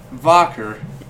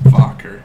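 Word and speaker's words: Wacker: It's called Vocker, but it's spelled Whacker. – Which is the funnest thing when they come into the bar with their shirts on. – It's pronounced Vocker.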